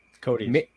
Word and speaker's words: Koteas: The guy playing Casey Jones looked a lot like Elias Koteas, or however you pronounce the actor's name. – Cody. – Thank you.